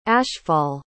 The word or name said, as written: ashfall